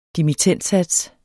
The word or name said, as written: dimittendsats